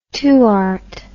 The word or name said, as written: tuart